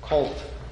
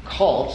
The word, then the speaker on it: colt: And believe it or not, I actually practiced saying that word colt this week because I tend to mispronounce it. It sometimes sounds like cult, C-U-L-T, a false religion, but we're speaking here of a colt, the foal of a donkey, a young animal.